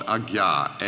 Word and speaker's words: Ajna: And then at the point between the eyes, which is called Ajna, A-J-N-A, both A's being long A's, which means command, the center of command, the soul, the energy, beholds in full radiance the beatific vision.